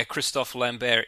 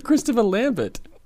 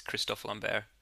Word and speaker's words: Christophe Lambert: I thought it was Christopher Lambert. – Yeah, it is if you're a dumb American who can't pronounce Christophe Lambert.